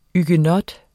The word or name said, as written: huguenot